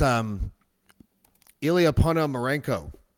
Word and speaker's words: Ponomarenko: That was Ilya Ponomarenko. Am I saying that right, Alex? – Yes. Yes. Okay, good.